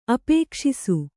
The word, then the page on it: ಅಪೇಕ್ಷಿಸು: kannada